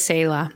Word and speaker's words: Selah: So if any of you guys know Hebrew, I would guess it would be Selah. No. – She says Selah.